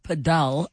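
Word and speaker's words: padel: And we continue in our masterclass on pedal, but it should be pronounced pedal apparently.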